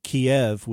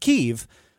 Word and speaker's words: Kyiv: So he reiterates the ask that we saw in Taylor's testimony, not to pronounce the city Kiev, which is the Russian word for it, but Kyiv, K-Y-I-V,